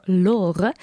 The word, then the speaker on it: Lore: Eh, de vraag die deze week is ingestuurd door Lore. Lor. Ik moest dat met een Gentse R uitspreken, maar ik weet niet zo goed wat een Gentse R is.